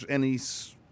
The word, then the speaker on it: anise: A little bit of licorice, black licorice, anise, however you say it. – Anise, whatever it is. – Anise. Some people say anise. I've always pronounced it anise.